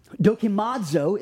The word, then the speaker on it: Dokimazo: Dokimazo is how you pronounce it.